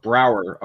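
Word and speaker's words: Braugher: Andre Bauer? – Brower. – All right. Andre Brower.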